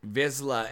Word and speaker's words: Vizsla: I mean, Star Wars pronunciations are weird, but Vizsla we can agree on. – Vizsla.